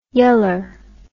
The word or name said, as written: yeller